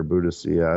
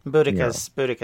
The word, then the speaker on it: Boudica: or Boudicca. I don't know what, Boudica is probably the correct way to say it, yeah. – That's I've always thought Boudicca sounded too Romanized.